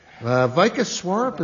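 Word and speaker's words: Vikas Swarup: Vika Swarup, is that how you pronounce that name? – Yes, that's who wrote it.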